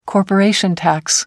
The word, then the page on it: corporation tax: American English